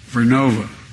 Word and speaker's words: Vernova: COSA makes wind towers that's going to go into turbines made by GE Verona. Excuse me. I mispronounced it. Vernova in Florida, New York.